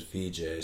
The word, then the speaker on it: Vijay: It's easiest when I tell people how to pronounce my name. To just do it's a V and a J. But it's really spelled V-I-J-A-Y. Like VJ Singh, like the golfer.